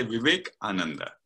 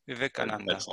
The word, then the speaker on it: Vivekananda: So yeah, I will put that name in the transcript of the of this podcast, The Complete Works of, not sure how I know how to pronounce it, Vivekananda. – Yeah, you can say Vivekananda. Yeah, Vivekananda. – That's how you do it.